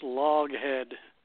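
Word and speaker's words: Loughead: This is pronounced Loghead.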